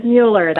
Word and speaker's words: Mueller: It is Mueller.